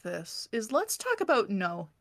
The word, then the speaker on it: No: It is spelled N-O. And pronounce that with, way. With a little accent over it. – To tell you it's a long O, so it is how you're supposed... So it is like the word no.